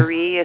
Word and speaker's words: Maire: Marie is spelled M-A-I-R-E.